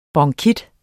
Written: bronkitis

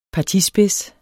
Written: partispids

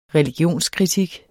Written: religionskritik

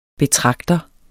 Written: betragter

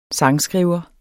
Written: sangskriver

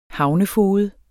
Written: havnefoged

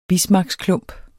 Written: bismarcksklump

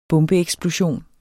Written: bombeeksplosion

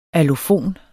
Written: allofon

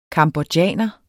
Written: cambodjaner

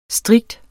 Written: strikte

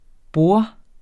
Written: borde

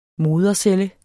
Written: modercelle

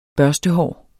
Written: børstehår

